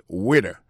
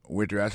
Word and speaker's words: waiter: Alors, moi, je prononce le waiter. Waiter, ça veut dire accent tombé sous premier syllabe là. Waiter. W-A-I-T-E-R.